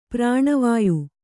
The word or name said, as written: ಪ್ರಾಣವಾಯು